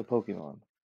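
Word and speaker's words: Pokémon: First of all, I don't appreciate you pronouncing it Pokemon. – How am I supposed Pokemon. – To say it? – It's Pokemon. – Pokemon? – Pokemon, yes. There's an accent Pokemon. On the E.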